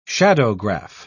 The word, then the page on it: shadowgraph: British English